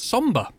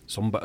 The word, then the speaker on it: Sombr: That's Somber. Somber. It's spelled S-O-M-B-R.